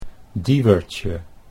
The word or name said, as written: Dieuwertje